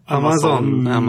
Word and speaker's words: Amazon: Har vi uttalat igen nu? – Ja. – Amazon? Eller? – Amazon.